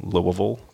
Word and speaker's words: Louisville: And so we moved down to Louisville, Kentucky, or if you want to say it like the locals do, Louisville, Kentucky. – Louisville. – Just say Louisville, but pretend your mouth is full of marbles.